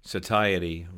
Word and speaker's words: satiety: The feeling you're talking about, the scientists call satiety, rhymes with society.